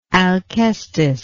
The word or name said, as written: alkestis